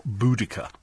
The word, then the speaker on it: Boudica: Boadicea or Boudica, depending on which pronunciation you like.